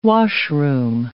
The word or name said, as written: washroom